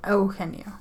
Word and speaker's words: Eugenio: In Eugenio. – No, it's E-U. Eugenio.